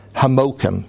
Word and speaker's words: Hamokim: the Yidden called Amsterdam Hamokim. That's how they pronounce it. It means the word Hamakom, right?